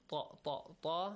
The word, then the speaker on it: ط: والبلد الطيب ط ط ط has a heavy sound. Make sure you pronounce it correctly.